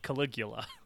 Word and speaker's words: Caligula: I believe that movie is actually pronounced Caligula.